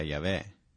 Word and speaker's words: Yahweh: Ci sono quelli che dicono Geova e quelli che dicono Yahweh. Tutto quello che abbiamo sono queste consonanti, YHWH. Loro non scrivevano le vocali, e così non sappiamo quale sia la vera pronuncia. Ma la maggior parte degli studiosi ritiene che sia Yahweh. Ma è la parola ebraica colui che diventa.